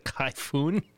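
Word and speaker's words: Khaifoun: Kaifun? I don't know how to say his name. Sorry. – It rhymes with typhoon. – Okay. It's K-H-A-I-F-O-U-N.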